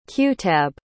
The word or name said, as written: qutab